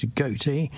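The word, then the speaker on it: Gotye: Now, Walter André Wally de Backer, better known by his stage name as Goatee, is a Belgian-Australian multi-instrumentalist and singer-songwriter.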